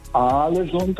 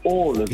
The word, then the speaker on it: Ålesund: Und zwei A's werden als O ausgesprochen und ein A mit einem Kringel oben drauf ist eigentlich auch eine Aussprache, ein O. Also das heißt nicht Alesund, das heißt Ålesund.